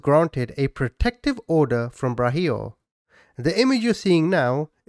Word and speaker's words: Braulio: And by the way, his name is spent Braulio, but I think the pronunciation is Brahio.